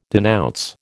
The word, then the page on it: denounce: American English